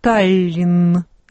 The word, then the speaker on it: Таллин: Надо ли отчетливо говорить, ну, допустим, «Таллин» или «Таллин»? – Безусловно, в конце слова произносится только одно «Н». «Таллин», конечно.